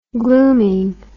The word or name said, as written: glooming